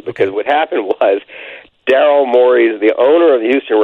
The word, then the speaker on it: Morey: do you pronounce it Morey? – Morey. – Yeah, Morey.